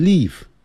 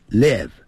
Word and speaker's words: live: 就是生活这个不要念成 live 不是 live 要短的元音 it live